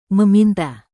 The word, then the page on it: meminta: indonesian